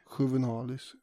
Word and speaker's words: Juvenalis: Satiriken Juvenalis, uttalas det så tror jag. – Säkert. – Juvenalis.